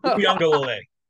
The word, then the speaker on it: Uiagalelei: Uiagalelei. – I don't watch his games. Right. I don't have the volume on when I have his games on. So maybe that's the reason why. – Uiagalelei, I believe, is the pronunciation.